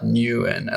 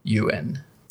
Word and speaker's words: Nguyen: I learned that her name is not Nguyen. I learned that that name, Nguyen, is pronounced Yuen.